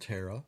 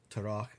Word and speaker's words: Terah: Now, there's a lot of traditions that surround Terah, or Terach, as depending on your pronunciation, and including, now get this, here is a legend that I don't know if we can believe or not.